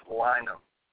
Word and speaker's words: Lynam: And don't feel bad because everybody butchers it. And it gets misspelled a lot of places we play too, but it's pronounced Lynam.